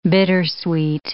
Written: bittersweet

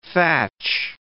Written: thatch

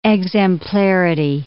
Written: exemplarity

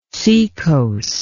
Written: secos

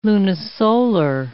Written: lunisolar

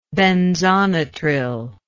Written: benzonitrile

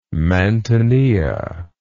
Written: mantinea

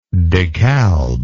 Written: DeKalb

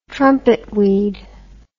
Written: trumpetweed

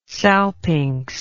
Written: salpinx

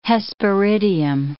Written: hesperidium

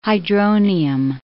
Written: hydronium

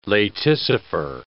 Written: laticifer

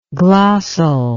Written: glossal

